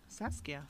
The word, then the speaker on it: Saskia: And her name, and I hope this I'm saying this right, is Saskia. Saskia? – Saskia.